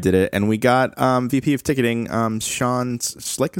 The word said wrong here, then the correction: Sitnick: is how you say his last name? Sitnick. – Sitnick, sorry.